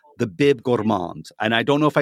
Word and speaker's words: gourmand: There's no D. You don't pronounce the D. Yeah. – If you put a D, it's gourmando. You're always trying to make it closer to Italian. It's just gourmand.